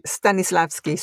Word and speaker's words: Stanislavski: Stanislavski, sí.